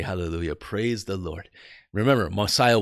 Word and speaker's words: hallelujah: And then it's not Jah, but it's Yah, hallelujah, like we pronounce it. It's pronounced the same all over the world, by the way.